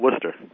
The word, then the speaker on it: Worcester: And for those who might be in Massachusetts, of course, those of us up there call it Worcester.